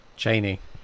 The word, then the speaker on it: Chani: Chani.